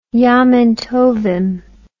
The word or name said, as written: yamin tovim